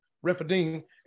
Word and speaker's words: Rephidim: Pronounce that word for me. How you pronounce it? Rephidim, and there was no water for people to drink.